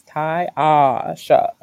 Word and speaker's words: Taiasha: And I say it that way so people can remember how to pronounce it. Like, it's Taiasha.